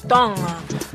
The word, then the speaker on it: Tonga: I don't ever want to say Tonga like Tonga, because it's much easier that way everyone can pronounce it. If I take this time to teach you how to pronounce it, will you please take the time to say it?